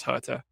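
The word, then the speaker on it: Hurta: Fair enough, but you'd think that Sage Herta would be Sage Herta, not Sage Erta, like they were saying on the Diamond League last week in Poland. – I think that's wrong. I haven't talked to Sage about it, but I assume it's Herta. I don't think it's the silent H.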